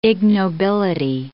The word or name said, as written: ignobility